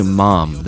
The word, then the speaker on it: Mame: His first name does not have an acute accent on the final E, so it is closer to mom than it is to mame.